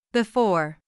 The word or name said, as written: before